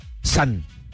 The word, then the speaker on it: san: En japonés son 3 rayitas horizontales y se pronuncia san.